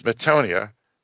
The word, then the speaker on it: metanoia: How does the dictionary say how to pronounce that? – It actually does. Metanoia? Yeah. So the T is pronounced as a D. Yeah, kind of interesting.